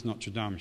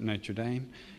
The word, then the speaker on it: Notre Dame: Should be pronounced Notre Dame, shouldn't it? But Americans pronounce it Notre Dame.